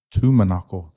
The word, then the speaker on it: Tumanako: My name is Jacob Tumanako.